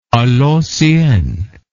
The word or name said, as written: a l'ancienne